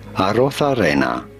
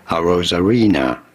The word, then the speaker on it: Arozarena: In Spanish, his name would be said as Arozarena. But in English, or in America, it is generally said as Randy Arozarena. Randy Arozarena. – Yeah, that's how Arozarena.